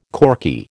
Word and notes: corky: American English

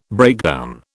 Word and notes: breakdown: British English

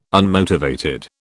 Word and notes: unmotivated: British English

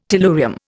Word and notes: tellurium: Indian

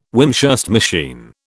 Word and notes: Wimshurst machine: British English

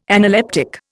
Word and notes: analeptic: Indian

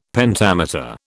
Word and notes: Pentameter: British English

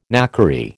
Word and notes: knackery: American English

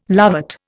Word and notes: lovat: Indian

